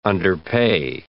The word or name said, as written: underpay